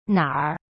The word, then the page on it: 哪儿: na r